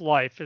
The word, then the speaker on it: Leif: He spelled it Leaf, L-E-I-F, but it is pronounced Life, as this article made sure to point out to me.